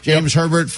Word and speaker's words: James Herbert: Also, 11 o'clock, James Herbert. Is that how you say his name? – Yep. – James Herbert from CBS NBA reporter.